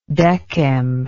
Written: decem

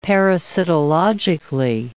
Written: parasitologically

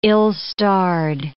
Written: ill-starred

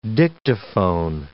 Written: Dictaphone

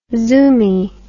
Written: zoomy

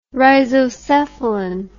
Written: rhizocephalan

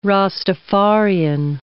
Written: Rastafarian

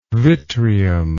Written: vitreum

